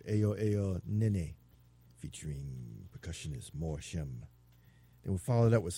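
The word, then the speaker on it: Mor Thiam: It spells his name M-O-R and last name T-H-I-A-M, but it's pronounced Morshim.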